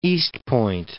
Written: Eastpointe

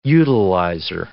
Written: utilizer

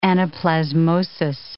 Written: anaplasmosis

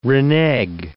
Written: renege

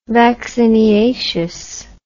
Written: vacciniaceous

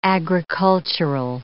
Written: agricultural

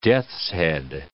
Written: death's-head

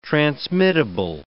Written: transmittable

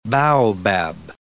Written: baobab

